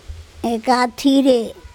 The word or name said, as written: ekatHire